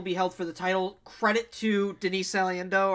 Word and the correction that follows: Salcido: or however you pronounce her name. – Salcido. Yeah, Salcido.